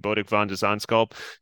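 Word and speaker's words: Botic van de Zandschulp: beating Bodek van de Zandschulp.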